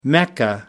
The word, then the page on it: mecca: American English